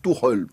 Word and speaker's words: Tuchel: Donc, on se met d'accord sur la prononciation, histoire qu'on soit homogène. – Alors, Jean-Charles Sabatier et David Lortolari, qui pour moi sont les deux meilleurs spécialistes du foot allemand, disent Tuchel. – Bon. Tuchel, ok. Voilà.